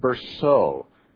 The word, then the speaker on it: Bercot: If I'm not mistaken, his name is pronounced David Berceau. But it's spelled B-E-R-C-O-T.